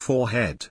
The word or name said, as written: 4head